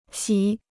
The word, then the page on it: 洗: xǐ